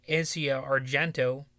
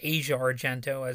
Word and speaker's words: Asia Argento: When Rose McGowan was asked about her friend Asia Argento, I believe that's how it's pronounced, or it's Asia Argento, as some might say.